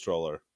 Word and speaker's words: Troller: Yeah, it's Troller, like the creature that lives under the bridge.